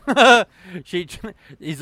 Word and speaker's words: Izanagi: Is that, how do you pronounce this? Izangi? – That works for me. – Okay. Izanagi. – Sounds like Izanagi. – Izanagi, yeah.